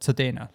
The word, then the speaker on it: Tsodena: I would imagine that, like, the way you pronounce it sounds like Sudena, like the German, like tsunami or zwei.